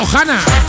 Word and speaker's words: Ohana: Se escribe Ohana, con la H ahí intercalada, pero se pronuncia Ohana.